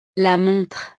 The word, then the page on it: la montre: frances